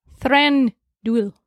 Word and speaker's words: Thranduil: Thranduil.